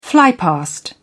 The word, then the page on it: flypast: British English